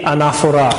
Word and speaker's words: Anaphora: So the next section is called the Holy Anaphora. Accent on the last letter. The Anaphora.